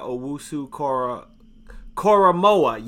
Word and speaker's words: Owusu-Koramoah: Jeremiah Owusu-Koramoah, yes? That's how you say it? – It's Owen Wu or J-O-K at Notre Dame, but yeah, that's how you say it.